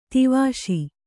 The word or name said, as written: ಧೀವಶ